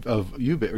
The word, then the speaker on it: Ubik: I feel like when I read the end of this book of Ubik, or Ubik, you're pronouncing Ubik. I said Ubik to my class all semester. Maybe I did it wrong. – It's supposed to be ubiquity. So yeah, I probably pronounce it wrong.